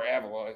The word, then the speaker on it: Avila: like, it, you know, the issue I have with El Vila is or Avila, as he would say, he likes to pronounce Avila.